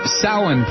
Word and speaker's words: Samhain: This is the Samhain program, or for those of you who don't use the Irish pronunciation, it is Halloween, our Halloween show.